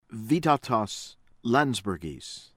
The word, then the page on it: Vytautas Landsbergis: American English